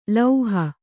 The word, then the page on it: ಲೌಹ: lauha